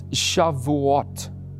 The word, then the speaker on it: Shavuot: because he said to me, it's Shavuot, Shavuot.